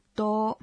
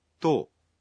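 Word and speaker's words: とお: El 10 es un caso especial. No se le añade tsu y se pronuncia to, ¿verdad? – Así es.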